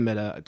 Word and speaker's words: Middagh: Ryan Middagh Music on Facebook.